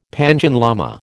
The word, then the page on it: Panchen lama: American English